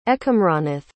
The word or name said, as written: Ekamranath